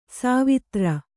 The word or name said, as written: ಸವಿದೋರು